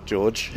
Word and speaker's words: Jorge: So, for example, I come up with a guy whose name is J-O-R-G-E space G-R-O-O-V-E, stage name. I was thinking I might pronounce it Jorge, but I remembered Portuguese, hard J, so that's George.